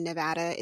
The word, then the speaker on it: Nevada: So for anyone who does not live in the state of Nevada, I'm going to say it again, Nevada, it's a short A, not a long A.